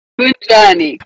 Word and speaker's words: Kunjani: Ujani. Did I say that right? – Kunjani. Kunjani with that strong K.